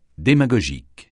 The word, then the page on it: démagogique: français